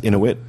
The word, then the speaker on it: Inuit: I believe it's they're pronounced Inuit. – Inuit.